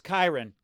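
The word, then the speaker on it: Kiran: Is that because it's short for Kyron, Kyran, or does he pronounce it Kieran, Kyron. – Or is it Kyron? We don't know. – It's Kyron.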